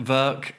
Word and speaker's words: Werke: Work Werke, uh, verk, whatever you want to do. I mean, it, it probably it's a German word. It probably should be Verke, but you know, I'm not that fussed.